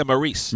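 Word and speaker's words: Maryse: So you obviously love the WWE, and you're gonna have to help me out with how to say this name. But well, the Miz and Maurice. Is that how? – Yeah, Maurice.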